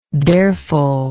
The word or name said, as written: dareful